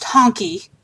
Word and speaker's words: Hunky: Whose name is Hunky, spelled Hunky, but pronounced Honky.